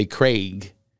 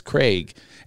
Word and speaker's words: Craig: In England, though, they say Craig, and uh, that's probably the right way to say it. It's not like an egg it doesn't rhyme with egg or Greg. It's Craig. Anyway, Michelle, Michelle.